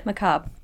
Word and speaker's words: macabre: It's the word that kept coming up all the time, which is macabre. Is that how you say it? – Yeah, macabre.